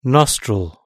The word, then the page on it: nostril: British English